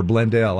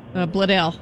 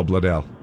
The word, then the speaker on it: Bledel: Or Blendell. I don't know how to say it. Do you? – Bledel. – Oh, Bledel.